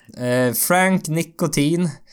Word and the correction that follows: Ntilikina: Frank Nikotin. Ja, jag kan fan jag har inte lärt mig uttala Det Det var Ntilikina. Ntilikina.